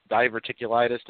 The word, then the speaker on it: diverticulitis: If I'm not mistaken, that's the one where Lesnar had diverticulitis.